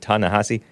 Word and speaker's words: Ta-Nehisi: Ta-Nehisi Coates is how it's spelled, but he pronounces it Ta-Nehisi.